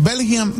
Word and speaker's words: Bellingham: Esto fue lo que dijo Bellingham, así se pronuncia.